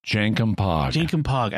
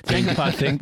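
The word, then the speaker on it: Jankom Pog: Jankum Pog. – I was going to say switch the J and the P. – Jankum Pog. Think Janky Pig.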